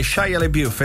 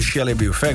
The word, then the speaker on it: Shia LaBeouf: Buongiorno ragazzi, ho sbagliato io la pronuncia, è Shia LaBeouf e non Shia LaBeouf, quindi Lali ti aveva detto bene, ci tenevo a dirlo, sull'attore di Transformer.